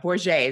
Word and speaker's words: Borget: Borget is how you pronounce my last name.